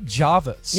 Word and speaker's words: Jervis: So it is spelled Jervis, but people from Sydney call it Jarvis.